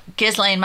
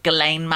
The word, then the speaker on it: Ghislaine: Oh, Ghislaine Maxwell.